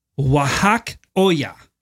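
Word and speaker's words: Wajackoyah: See right there, Mr. Wajakatodaya. – I'm going to say his name is Wahak Oya.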